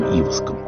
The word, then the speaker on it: Иваск: Владимир Варшавский в беседе с Юрием Иваском. Я делаю такое ударение в фамилии Иваска, потому что в науке об эмиграции так прижилось.